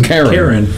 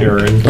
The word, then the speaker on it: Charon: So Charon apparently can be pronounced differently, but it just... – Karen. – Yeah. – Karen yeah. – the ferryman. – But there's I think, a third pronunciation as well.